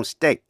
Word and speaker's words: steak: EA phát âm là A trong steak. Beef steak.